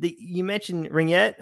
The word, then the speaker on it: ringette: I like how you mentioned ringette. Am I saying it correctly? Ringette? – Yeah.